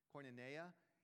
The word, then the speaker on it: koinonia: Koinonia in the original Greek, that's how it's said in the original Greek, koinonia, which most Christians these days translate as the word fellowship.